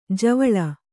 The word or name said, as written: ಜ್ವಾಲೆ